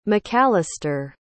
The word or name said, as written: macallister